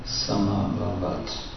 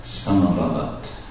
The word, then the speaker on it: samabhavat: Tasya nabe samabhavat. – Padmakosho Hiranmaya. – Padmakosho Hiranmaya. – Tasmin Jagnye Maharaja. Svayambhush Chaturanana. – Svayambhush Chaturanana. – The second syllable in Samabhavat is short. Samabhavat. It's not Samabhavat. It's Samabhavat.